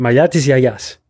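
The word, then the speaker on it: Mayatis Yayas (μαλλιά της γιαγιάς): Nein, also, wie, wie, wie würdest du es Griechisch aussprechen? Mayatis Yayas.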